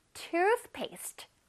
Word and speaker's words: toothpaste: Toothpaste. One more time. Toothpaste. 치약이야, 얘들아. One more time. Toothpaste. 이것도 마찬가지로 중간에 뜨 발음 있다. 혓바닥으로 실뜨기 하는 거 잊지 마세요. Toothpaste. Toothpaste. 이것도 앞부분에 강세가 있어요.